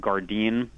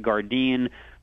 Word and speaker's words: Gardein: products like Gardein, a great product. It's spelled G-A-R-D-E-I-N.